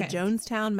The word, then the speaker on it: Jonestown: The Jonestown Massacre, we can call it.